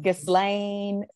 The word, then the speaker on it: Ghislaine: How do you pronounce it? The Giselle. – Ghislaine, Ghislaine Maxwell.